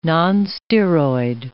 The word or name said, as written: nonsteroid